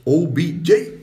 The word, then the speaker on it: OBJ: A alza, eh, OBJ.